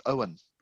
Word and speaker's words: Eoin: Having met him a few years ago, I think he would want me to tell you that it's pronounced Owen. It's spelt a funny way, but it's Owen Colfer. It's the Irish spelling.